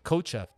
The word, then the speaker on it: Kotcheff: Kotcheff.